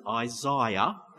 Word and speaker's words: Isaiah: In Luke chapter 4, verse 17, Jesus goes into the synagogue, and the scroll of the prophet Isaiah, and that's how you pronounce it, Isaiah, was unrolled.